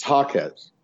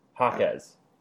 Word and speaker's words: Jaquez: Are we just pronouncing his last name different ways every time? – It's Jaquez. – Yeah, Jaquez.